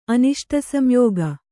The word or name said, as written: ಅನಿಷ್ಟಸಂಯೋಗ